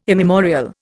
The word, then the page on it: immemorial: Indian